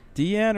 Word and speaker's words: Diane: Deanne or Diane? – Deanne. – God damn it, it's Deanne. – It's spelled like Princess Diane. – Well, that's not how it's pronounced.